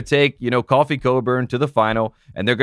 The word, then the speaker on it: Cockburn: Everyone is very excited to say Cockburn when it's Coburn, but you say Coffee for Kofi.